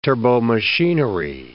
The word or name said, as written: turbomachinery